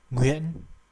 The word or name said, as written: Nguyen